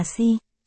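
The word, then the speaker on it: si (四): Trong tiếng Trung, số 4 đọc là si, si. Nghe gần giống chữ chết si, si.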